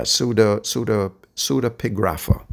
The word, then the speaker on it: pseudepigrapha: Pseudographia, if I'm pronouncing that right. Yeah, pseudepigrapha, I'm sorry.